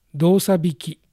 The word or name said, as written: Dosabiki